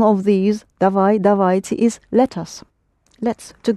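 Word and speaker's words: Davajte (давайте): Dvajeti. – Davajte. A will be accented. Davajte. Pronounce it again, please. – Dvajeti. – Yes, davajte.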